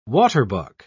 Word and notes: waterbuck: British English

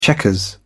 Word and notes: checkers: British English

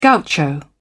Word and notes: gaucho: British English

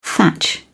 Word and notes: thatch: British English

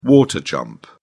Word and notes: water jump: British English

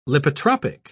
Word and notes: lipotropic: British English

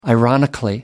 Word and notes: ironically: British English

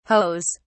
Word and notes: hoes: British English

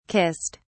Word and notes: kissed: British English

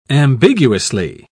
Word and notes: ambiguously: British English

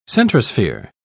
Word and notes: centrosphere: British English

